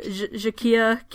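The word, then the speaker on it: Jacquire: I want to say Jacquier. – Yeah, that's cool. – So it's spelled like a choir, but with a J at the front. – Yeah. – So Jacquier King.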